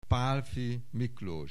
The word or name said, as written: Pálffy Miklós